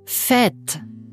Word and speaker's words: fête: Auch das E mit Hut, das Accent circonflex, wird Ä ausgesprochen. – Fête, Tête, Même, Arrêtez.